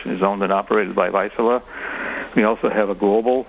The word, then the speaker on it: Vaisala: What kind of work do you do at Vaisala, which is spelled V-A-I-S-A-L-A? – Yes, it's Vaisala. It's a Finnish company, headquartered out of Finland.